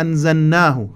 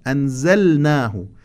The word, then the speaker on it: أنزلناه: ولقد علمتم الذين اعتدوا منكم في السبت فقلنا لهم كونوا قردة خاسئين، ولحظة تجويدية ظريفة طبعا، فقلنا لهم، بما أن اللام والنون يخرجان من موضع واحد، اللام والنون والراء هذه الأحرف الثلاثة تخرج من موضع واحد، ومن حيث النطق ومن حيث التلفظ تكون متقاربة جدا، ولهذا البعض حينما يقرؤون هذه الكلمة بسرعة يدغمون اللام في النون، فقلنا لهم هكذا يقرؤون، أو مثلا في سورة القاد إنا أنزلناه، خطأ، أنزلناه فقلنا لهم، يجب إظهار اللام بصورة جلية وواضحة.